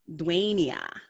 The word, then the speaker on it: Dwania: Dwania. – Dwania. – Yeah. – So it's the long A.